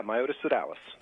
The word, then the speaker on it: Myotis sodalis: How do you say that? – Yeah, Myotis sodalis. That's right.